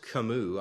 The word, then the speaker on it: Camus: Well, Albert Camus, I believe is how his name is pronounced, C-A-M-U-S, a 20th century philosopher, he was known for statements like this: knowing we all die makes life a joke.